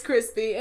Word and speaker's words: Chrispy: But my name is Chrispy, and that's spelled C-H-R-I-S-P.